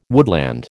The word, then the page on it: woodland: American English